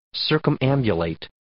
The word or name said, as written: circumambulate